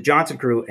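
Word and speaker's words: Jonzun Crew: And so this is the Johnson Crew, and it's spelled J-O-N-Z-U-N Crew.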